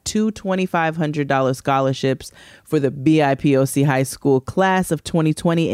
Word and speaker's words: BIPOC: BIPOC. – Because I don't know how to say it. BIPOC.